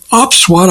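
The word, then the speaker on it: OPSWAT: We're going to talk today with Benny Charney, who is founder and CEO of OpsWatt, I believe that's called. Benny, good to have you with us today. Did I pronounce that correctly? – You got it right.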